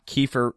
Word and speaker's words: Keepfer: If you want to follow him about the Clemson Tigers leading up to the game, it's Scott Kiefer. There's a silent P in there. Scott, K-E-E-P-F-E-R.